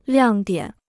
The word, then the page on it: 亮点: liàng diǎn